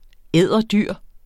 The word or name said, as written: edderdyr